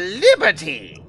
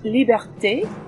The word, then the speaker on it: Liberté: Mademoiselle Liberty! – It's pronounced Liberté, and it's Madame.